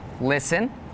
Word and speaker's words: listen: Some examples with a silent T are the words listen, the word castle, and the word Christmas.